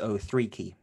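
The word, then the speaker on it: Okeke: Well, yeah, I mean, just if we go off the basis of who the Magic are, you've got people like Okiki getting a lot of minutes. You've got Ken Birch, who's also... – You got his name wrong. It's O3Key.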